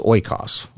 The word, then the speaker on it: oikos: And the word is oikos, okay? And that would be maybe how you'd pronounce it if you're talking about biblical Greek.